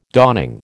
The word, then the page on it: dawning: American English